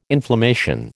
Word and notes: inflammation: American English

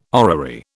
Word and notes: orrery: British English

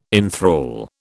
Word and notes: enthral: British English

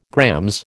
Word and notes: GR: American English

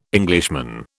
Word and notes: Englishman: British English

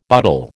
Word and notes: butle: American English

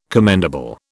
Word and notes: commendable: British English

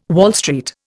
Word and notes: Wall Street: Indian

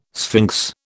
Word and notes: sphinx: British English

